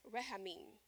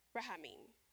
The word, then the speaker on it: rahamim: So there's a Hebrew word for compassion, and it's used interchangeably with mercy, and it's pronounced rahamim, rahamim. That's compassion or mercy in the Hebrew.